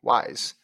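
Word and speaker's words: Weiß: Oh wow, it's a double S in German. So Weib is pronounced like wise. Thank you, wise.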